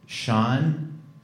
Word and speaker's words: Sean: My name's Sean. – Who? – Sean. – Sawa. – Sean, spelled S-E-A-N.